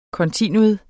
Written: kontinuum

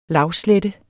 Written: lavslette

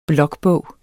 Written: blokbog

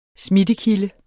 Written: smittekilde

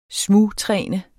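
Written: SMUGTRÆNET

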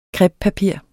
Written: crepepapir

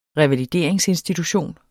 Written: revalideringsinstitution

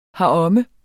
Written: heromme

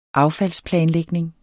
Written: affaldsplanlægning

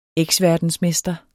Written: eksverdensmester

